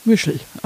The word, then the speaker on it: Mischel: Michel, wie Michel. M-I-S-C-H-E-L. – Michel. – Ah. Ja. Ich hoffe, der wird so ausgesprochen.